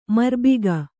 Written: ಮರ್ಬಿಗ

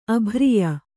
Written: ಅಭ್ರಿಯ

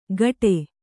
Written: ಗಡೆ

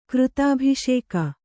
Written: ಕೃತಾಭಿಷೇಕ

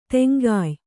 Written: ತೆಂಗಾಯ್

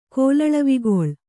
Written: ಕೋಲಳವಿಗೊಳ್

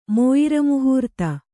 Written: ಮೋಯಿರಮುಹೂರ್ತ

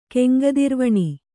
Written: ಕೆಂಗದಿರ್ವಣಿ

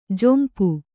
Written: ಝೊಂಪು

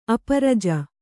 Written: ಅಪರಜ

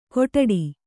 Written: ಗೊಡ್ಡಿ

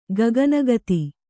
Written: ಗಗನಗತಿ

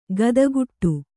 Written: ಖಾತಿಗೆಡು

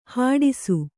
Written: ಹೆಡಸು

